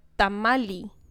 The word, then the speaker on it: tamalli: Y eso sí, y en náhuatl, tamal se decía tamali. Pero ah. T-A-M-A-L-L-I. – L-L-I? – Yeah, tamali.